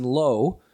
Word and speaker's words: Loo: So that's why it's Jason Lowe, but it's spelt L-O-O. – Yeah. – And we've been calling him Jason Lou for fucking 10 years.